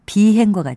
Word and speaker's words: 비행: 둘 다 안일 비자를 쓰고 발음은 비리, 비행과 같이 길게 합니다.